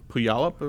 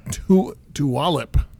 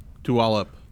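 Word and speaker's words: Tulalip: how do you pronounce the city? Apparently I pronounced it wrong. – Puyallup or something? – No. Tualip. – Tualip.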